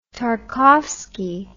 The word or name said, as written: Tarkovsky